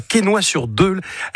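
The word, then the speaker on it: Quesnoy-sur-Deûle: Tu habites à Quesnoy-sur-Deule.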